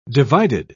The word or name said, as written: divided